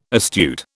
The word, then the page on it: astute: British English